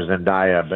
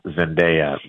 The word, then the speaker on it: Zendaya: And for years I called her Zendaya, but she came out and said the correct pronunciation actually is Zendaya, even though it still feels kind of odd to my ear.